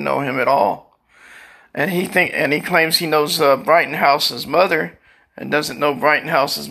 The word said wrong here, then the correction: Rittenhouse: Wife had to correct me on my, how do you say it? – Rittenhouse. – Rittenhouse.